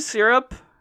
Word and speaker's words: syrup: Do you have any syrup? – I have soup. – No, not soup. – I have syrup. – Syrup, two syllables.